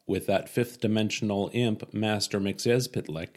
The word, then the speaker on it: McYezpitlick: I know it says it right here. Pronounced McYezpitlick. – Yes. – McYezpitlick. – McYezpitlick. – Now, of course, I grew up watching the Super Friends cartoons. – Well, they'd do it all wrong. – Yeah, they call him Mr. Mixelblick. – That's wrong. – Yeah. – They should have read this text box, which teaches you how to pronounce it.